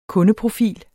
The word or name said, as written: kundeprofil